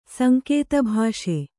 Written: ಸಂಕೇತಭಾಷೆ

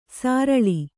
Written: ಸರಳೆ

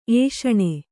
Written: ಏಷಣ

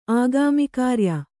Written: ಆಗಾಮಿಕಾರ್ಯ

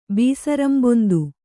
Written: ಬೀಸರಂಬೊಂದು

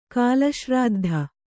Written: ಕಾಲಶ್ರಾದ್ಧ

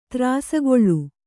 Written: ತ್ರಾಸಗೊಳ್ಳು

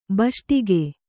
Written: ಭಾಷ್ಟಿಗೆ

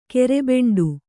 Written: ಗೋರಿಬೇಂಟೆ

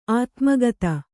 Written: ಆತ್ಮಕಥೆ

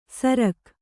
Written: ಸರ್ಗ

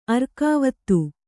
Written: ಆರಗ್ವಧ

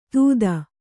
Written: ಧೂತ

